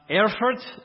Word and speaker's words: Erfurt: And here is this Augustinian monastery in Erfurt. Did I say that right? Yeah, close enough. He's trying to correct me over there in my southern. I'll say close enough.